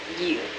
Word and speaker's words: ewe: It's funny spelled. It's spelled E-W-E. – You.